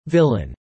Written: villain

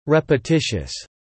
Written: repetitious

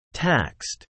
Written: taxed

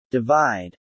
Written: divide

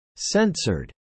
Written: censored

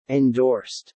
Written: endorsed

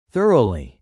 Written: thoroughly

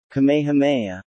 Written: Kamehameha

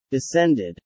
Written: descended

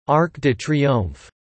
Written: Arc de Triomphe

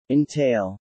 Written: entail

